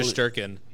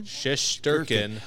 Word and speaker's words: Shesterkin: Shesterkin. – How do you say that again? – Shesterkin.